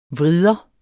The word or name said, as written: vrider